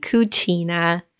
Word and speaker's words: Cucina: Um, so it's QB Cucina, um, which is the Italian pronunciation.